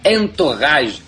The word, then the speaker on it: Entourage: Entourage.